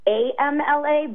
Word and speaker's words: Amla: It's AMLA, but it's pronounced with so Amla.